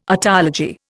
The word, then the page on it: otology: Indian